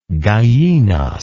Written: Gallinas

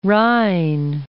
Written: Rijn

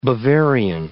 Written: Bavarian

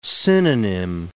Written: synonym

